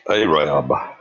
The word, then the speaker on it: Arab: Okay, the town is pronounced Arab.